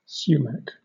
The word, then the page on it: sumac: British English